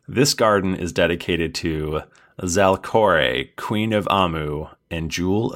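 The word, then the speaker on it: Zalcoré: That's Z-A-L-C-O-R and then an E with an accent over it. I've decided that it's pronounced Zalkore.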